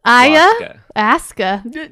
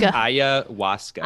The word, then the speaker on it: Ayahuasca: It looks like it's spelled Ayahuasca, but it's pronounced Ayahuasca. – Ayahuasca. – Like almost like with the W, like Ayahuasca.